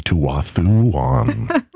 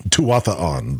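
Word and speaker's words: Tuatha'an: I think what he's referring to there is the Tuathuan. Tuathuan. Tuatha on.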